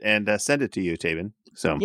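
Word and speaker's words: Taybin: It's spelled different, but it's pronounced, it's T-A-Y-B-I-N, but it's Tabin. – Tabin.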